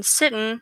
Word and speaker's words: Sitton: My full name is Marissa Sitton, Sitton. And I was like, all right, well, I have to incorporate like me somehow. And Sitton rhymes with kitten.